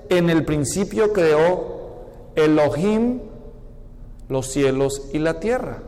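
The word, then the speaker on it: Elohim (אלהים): Y dioses se dice Elohim. La terminación him es el plural masculino en el idioma hebreo.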